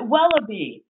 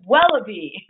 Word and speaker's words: Wellaby: You pronounce it Wellaby. Like, I don't know, it reminds me of a bar in Ames called Wallabies, but it's Wellaby.